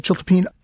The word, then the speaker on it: chiltepín: One of your first stops was Sonora, Mexico, in search of the chiltepín.